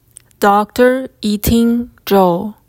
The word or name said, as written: Dr. Yiting Zhou